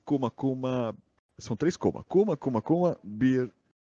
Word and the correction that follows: Bear: É Beer, é Bear, né? Bear de urso, ó.